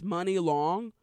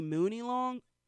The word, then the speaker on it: Munilong: I guess it's Money Long. To me, it should be Mooney Long, but what do I know? It's spelled M-U-N-I-L-O-N-G.